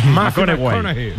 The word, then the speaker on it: McConaughey: Ojo, no, fichero, le gusta, hay que ir corrigiendo la pronunciación. – McConaughey. Matthew McConaughey. Bueno, pues el Matías.